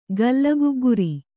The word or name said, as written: ಗಲ್ಲಗುಗ್ಗುರಿ